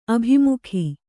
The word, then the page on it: ಅಭಿಮುಖಿ: abhimukhi